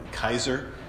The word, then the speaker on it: Caesar: Yeah, so down here, this says Neron, N-R-O-N, basically. And then Caesar, we would say Caesar, the way you would pronounce it here is more like Kaiser, like they would say in Germany, I think, if I remember.